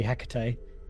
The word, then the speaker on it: Hecate: So as in case anyone doesn't realise, that should be Hecate. – I mean, I was wondering if it was just another pronunciation, but I know in Shakespeare it's Hecate, isn't it? So it's not even Hectate, you know. That is just a complete butchering of the name.